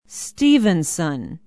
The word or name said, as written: stephenson